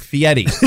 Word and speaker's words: Fieri: But it's spelled F-I-E-R-I. Where's the T come from? – Right by where he signs my check, Fieri. – Does he pronounce it Fieri? – Everybody does. The birds do.